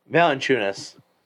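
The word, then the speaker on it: Valančiūnas: Jonas Valanciunas or what? However you say his name? – Valanciunas. – Yeah, there you go.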